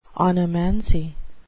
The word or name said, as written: onomancy